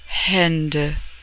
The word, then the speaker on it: Hände: The umlaut, two dots over the A in H-A-N-D-E, is pronounced E, Hände.